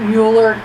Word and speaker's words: Mueller: Why are they calling him Mueller? Mueller? – I think that's how he pronounces it. – Shame on him. It's Mueller. Okay, he is German through and through. He's trying to escape his German roots. He probably has some people that were tied to the Nazi party. – Come on. – And that's why. His name is Mueller.